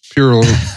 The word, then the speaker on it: Perrault: Pronounce that name. – Charles Perrault. – Yeah, that's the one we're keeping.